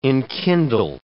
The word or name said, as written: enkindle